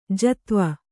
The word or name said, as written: ಜತ್ವ